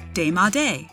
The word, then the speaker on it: démodé: The word of the day is démodé, spelled D-E-M-O-D-E, but note that both E's are spelled with an acute accent.